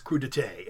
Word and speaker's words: crudité: It's pronounced crudité.